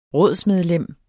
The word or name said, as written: rådsmedlem